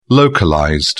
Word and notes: localized: British English

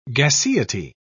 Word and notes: gaseity: British English

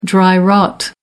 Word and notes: dry-rot: American English

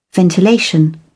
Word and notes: ventilation: British English

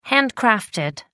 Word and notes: handcrafted: British English

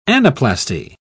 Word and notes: anaplasty: British English

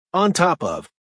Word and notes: on top of: American English

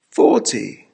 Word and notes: fourty: American English

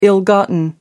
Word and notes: ill gotten: American English

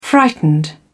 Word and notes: frightened: British English

